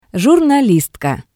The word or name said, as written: jurnalistka